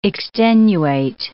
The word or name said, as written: extenuate